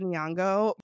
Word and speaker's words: Nyong'o: I remember when Lupita Nyong'o, I'm going to mispronounce her last name. – Oh my gosh. Lupita Nyong'o.